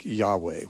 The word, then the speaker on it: Yahweh: More commonly, you know, Hebrew scholars would, I think, endorse a pronunciation more like Yahweh, which is, you hear that much more often now by those who are trying to be authentic.